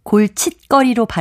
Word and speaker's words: 골칫거리: 고이어 골치와 거리가 합해진 것이라 골칫거리로 발음되기 때문에 사이시옷을 쓰는 겁니다.